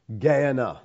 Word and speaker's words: Gehenna: Gehenna. That's the word here. That's the Greek pronunciation anyway. People will pronounce this all kinds of ways, but you're stuck with mine for now.